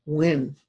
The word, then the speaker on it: Nguyen: And so I just got the pronunciation Win.